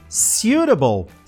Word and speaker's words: suitable: Olha aí, ó, a gente já pode chegar na pronúncia de suitable. Suitable. Se um tá bom.